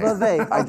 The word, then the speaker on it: Vivek: Vivek.